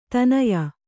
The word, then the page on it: ತನಯ: tanaya